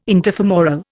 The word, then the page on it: interfemoral: Indian